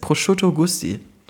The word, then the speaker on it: Prosciutto Gusti: Nee, Prosciutto wie Schinken und Gusti wie Gustav Drüner ist doch mein alter Ego.